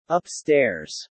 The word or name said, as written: upstairs